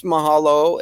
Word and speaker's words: mahalo: Yeah, it's mahalo. It's like your vowels are pronounced. Your A is ah. Okay. You know, and so, and your O is O. So mahalo is how you say it.